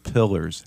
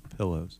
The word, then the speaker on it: pillars: Welcome to Masculine Journey After Hours, and we are in the middle of a series of pillars. Hey, I said it right that time. If you listen to blankets. – No blankets. No sleep number beds, nothing. Pillars, not pillows.